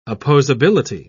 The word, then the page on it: opposability: British English